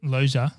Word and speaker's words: Loggia: Is that how you pronounce it? – Loggia. It is. – It is? – Yeah, I've heard it said many times. I'm confident in that.